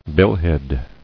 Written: Billhead

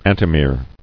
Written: Antimere

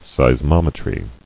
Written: Seismometry

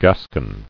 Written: Gascon

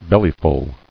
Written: Bellyful

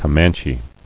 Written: Comanches

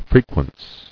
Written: Frequence

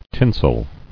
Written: Tinsel

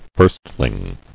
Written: Firstling

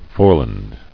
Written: Foreland

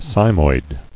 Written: Cymoid

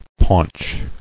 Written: Paunched

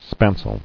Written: Spanceling

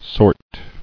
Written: Sortable

